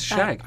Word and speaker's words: Shug: You're pronouncing it right. It's Shag. – I am. S-H-U-G.